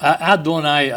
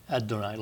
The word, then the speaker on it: Adonai: All right, Adonai, I think your best pronunciation for the D is to take a TH sound and make it sound like a D. The Adonai, like it's not TH but DH, Adonai.